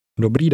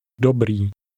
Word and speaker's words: Dobrý: Dobrý. – Notice the pronunciation of the ending.